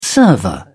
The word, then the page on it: server: British English